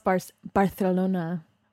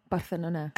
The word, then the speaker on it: Barcelona: It's pronounced Barcelona. Barcelona.